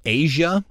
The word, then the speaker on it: Aja: Do you know the Steely Dan album and song Asia? – Yes. – Now, Asia is not spelled A-S-I-A. – Mm-hmm. – It's spelled A-J-A.